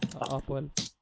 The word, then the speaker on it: Apoel: Hur fan uttalar man det? – Apoel. Ja.